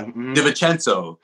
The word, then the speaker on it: DiVincenzo: DiVincenzo, yeah, DiVincenzo. I always forget the syllable, or I always try to add it.